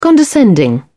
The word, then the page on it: condescending: British English